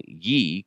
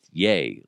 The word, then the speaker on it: Ye: I kept saying ye, because it's spelled Y-E. He's like, dude, it's yay, like Y-A is the, like how you're supposed to say it, Kanye.